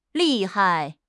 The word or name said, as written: 利害